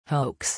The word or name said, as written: hoax